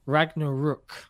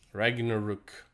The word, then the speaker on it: Ragnarok: I've just been seeing in the chat the correct way, and I've just done a little Google, and it's Ragnarok. – Ragnarok.